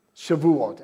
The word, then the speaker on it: Shavuot: Shavuot.